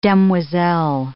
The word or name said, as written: demoiselle